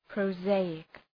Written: prosaic

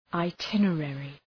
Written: itinerary

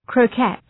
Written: croquette

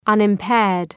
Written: unimpaired

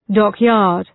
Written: dockyard

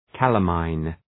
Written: calamine

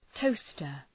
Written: toaster

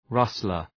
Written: rustler

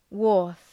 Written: wharf

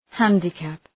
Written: handicap

